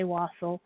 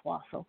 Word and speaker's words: wassail: Some people say wassail. If I said it wrong, it's usually pronounced wassail.